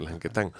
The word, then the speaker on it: Lanquetín: Lanquetín se escribe, pero se dice Lanquetán.